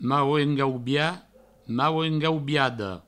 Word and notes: mauengaubiat: mauengaubiada; mauengaubia; mauengaubiado

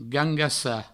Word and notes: gangassar: gangassa

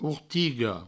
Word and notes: ortiga: ourtigo

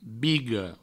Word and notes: biga: Provençal; bigo

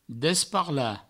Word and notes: desparlar: Provençal; desparla

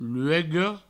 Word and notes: luega: provençal; luego

